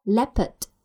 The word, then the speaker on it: leopard: 那我们在读这句话的时候呢，要注意 leopard 的读音，前面是 a，嘴巴是微微打开，下巴放松， a a leopard。